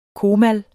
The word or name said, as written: comal